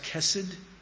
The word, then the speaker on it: chesed: God's chesed. That's the Hebrew. That's how you pronounce it, chesed.